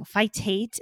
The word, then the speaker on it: phytate: Phytate and phytase.